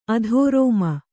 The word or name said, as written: ಅಧರ್ಮ